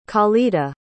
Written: calida

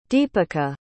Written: deepika

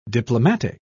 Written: diplomatic

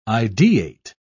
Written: ideate

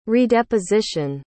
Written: redeposition

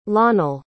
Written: lonell

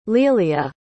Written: lealia

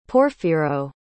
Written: Porfiro

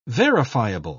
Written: verifiable

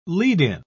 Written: lead-in

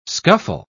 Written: scuffle

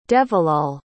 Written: devilal

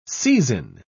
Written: seisin

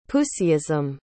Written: puseyism

